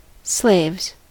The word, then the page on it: slaves: American English